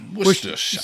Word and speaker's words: Worcestershire: Worcestershire is really pronounced sure. – Sure. – That's Worcestershire. And you can either pronounce the W-O-R-C-E as Worcha or Worse.